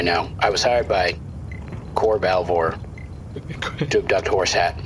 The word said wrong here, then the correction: Balvor: It's pronounced Balvor, but I mean, it makes sense. If you're reading it, you probably yeah, I've never said it out loud before. – Okay, yeah, no, it's Balvor.